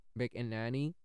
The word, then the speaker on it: McAnany: At 3:40 p.m., White House Secretary Kayleigh McAnany, McNanny, sorry, Mc, McAnany, that's how you pronounce it, said the National Guard and other federal protective services had been deployed.